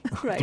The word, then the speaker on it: thrips: And it is always pronounced with a plural, thrips. – Thrips. – Even though there's one of them, it's still a thrips.